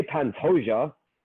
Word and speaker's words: Pantoja: Ah, from here, uh, the first fight of the main card is going to be a flyweight bout between Alexandre Pantoja, good good pronunciation there, taking on up and comer Askar Askarov.